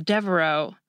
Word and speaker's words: Devereaux: You just add an S to the end because unlike the name Fox, you don't pronounce the X at the end of Devereaux, so it just gets a lone S to make it plural.